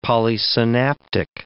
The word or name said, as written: polysynaptic